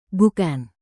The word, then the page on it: bukan: indonesian